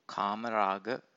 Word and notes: kamaraga: Pali

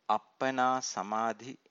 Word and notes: appana samadhi: Pali

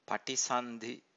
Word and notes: patisandhi: Pali

